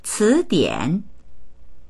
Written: ci2dian3

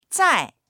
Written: zai4

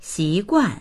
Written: xi2guan4

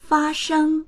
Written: fa1sheng1

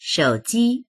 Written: shou3ji1

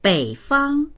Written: bei3fang1

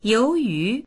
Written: you2yu2

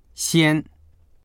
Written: xian1

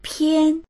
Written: pian1

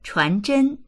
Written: chuan2zhen1

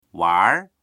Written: wanr2

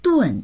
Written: dun4

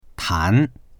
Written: tan2

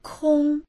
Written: kong1

